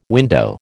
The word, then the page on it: window: American English